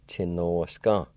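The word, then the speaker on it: tsinosga: Chi, no, s, ga. Chi, no, s, ga. Chi, no, s, ga.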